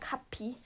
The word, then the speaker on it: Kappi: Und den schreibt man halt einfach auch so mein Na Also dann schreibt man halt einfach so, wie man sagt, Kapi. – Es ist halt nicht so Kapi, irgendwie so mega kompliziert, sondern K-A-P-P-I.